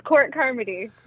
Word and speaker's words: Court Carmody: Which is Court Carmody.